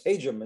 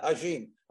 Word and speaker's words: Hajim: It's Hajim and not Hajim. Hajim sounds better, but Hajim's much better.